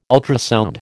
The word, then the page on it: ultrasound: American English